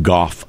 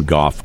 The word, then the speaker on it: Gough: There was a British radio host named Tim Goff, I think is how you say his name, G-O-U-G-H, Jim Goff, I believe.